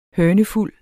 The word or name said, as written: hønefuld